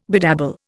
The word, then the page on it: bedabble: Indian